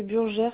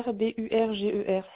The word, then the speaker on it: Burger: Oui, c'est Burger, Burger, ça s'écrit comme burger.